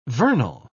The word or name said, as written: vernal